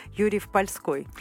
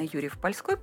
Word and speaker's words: Юрьев-Польской: А, интересно, вы делаете ударение совершенно такое нетрадиционное, да, Польской. Все-таки правильно Юрьев-Польской. – Юрьев, да, правильно Юрьев-Польской, потому что он никакого отношения к Польше не имеет, а находится посреди Аполья, русского Аполья, поэтому это ударение от слова «поле».